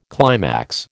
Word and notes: climax: American English

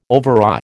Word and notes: overawe: American English

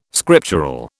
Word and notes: scriptural: British English

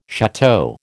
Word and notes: château: American English

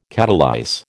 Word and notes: catalyse: American English

